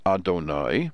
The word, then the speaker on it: Adonai: which we are not allowed to pronounce as written. We pronounce it as Adonai, which means my Lord, my Master.